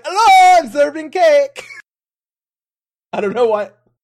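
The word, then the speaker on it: Zurvan: Let's talk about the Warring Triad, and I guess it's pronounced Zervan, not Zervon.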